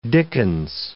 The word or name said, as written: dickens